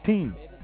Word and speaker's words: tien: T-I-E-N. Team. Like teen spirit. Teen is the number 10. Teen. T-I-E-N. Teen. Moving on. Elf. Alf. Like said Alf. A-L-F. But it's E-L-F.